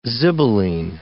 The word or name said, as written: zibeline